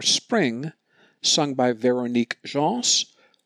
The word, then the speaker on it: Véronique Gens: We will hear the French soprano Véronique Gens. I believe that's how she says her name. G-E-N-S, like Jean, only with the S pronounced.